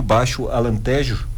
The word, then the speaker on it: Baixo Alentejo: Baixo Alentejo.